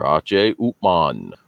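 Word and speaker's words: H. Upmann: Yeah, and that's how they pronounce it over in Germany. – I didn't know that. – Yes, sir. H. Upman is the correct pronunciation for this particular cigar.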